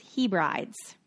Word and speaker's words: Hebrides: outer how do you say that? Hebrides? I should have learned how to say that before I got on this mic, huh? – Hebrides. – Hebrides.